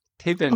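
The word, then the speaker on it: Taybin: It's spelled different, but it's pronounced, it's T-A-Y-B-I-N, but it's Tabin. – Tabin.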